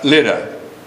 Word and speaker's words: Lydda: Now notice he said in verse 32, the saints which dwelt at Lydia. At Lydda. Boy, I'm not saying that word right, I don't think. According to the commentators, Lydda is actually how it's pronounced.